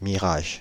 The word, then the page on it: mirage: France (Île-de-France); IPA: /mi.ʁaʒ/; Paris: IPA: [mi.ʁaʒ]